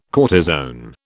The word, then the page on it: cortisone: British English